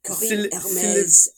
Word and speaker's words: Corinne Hermes: Is that pronunciation correct? – Yeah, Corinne Hermes.